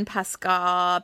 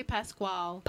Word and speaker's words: Pasquale: Uh, it featured Sutton Foster as Queenie, Steven Pascal, Pasquale. I never know how to say it's, his it's last pa- name. – Uh, Pascal? – Pasquale. They say Pasquale. – Pasquale. Okay, I'm going to hope that's right.